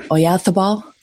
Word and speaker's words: Oyarzabal: Like, you can't stop them, but if you get Simon into a pattern where he's looking for, say the name again, Oyarzabal. Did I say it right? – You nailed it.